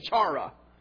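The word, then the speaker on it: chara (χαρά): Or I would have said chara or something like that. But it's chara. And you kind of roll the H.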